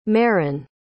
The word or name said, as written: meron